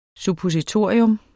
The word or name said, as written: suppositorium